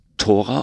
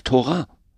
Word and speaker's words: Tora: Und genau diese Frage, das finde ich das Spannende, weil es ist sowohl als auch richtig, ja, also Tora wäre hebräisch, während Tora eine deutsche Aussprache ist, eine rein deutsche Aussprache, aber eigentlich gibt es dieses Wort Tora nicht. Es ist einfach nur nach deutschen Regeln, Ausspracheregeln, weil es mit Thora geschrieben ist, ja, aber wenn man es auf Hebräisch schreibt, ist es natürlich nicht so.